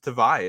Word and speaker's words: Tavai: you're saying his name wrong and you meet him in person, you find out the pronunciation on the Patriots site that he is saying isn't it's it's just Tavai is his last name.